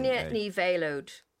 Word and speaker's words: Grainne Ní Mhéalóid: Grainne NÍ Mhéalóid. So you pretend it's like a veil that you wear at a wedding, an ode knee, like your knee.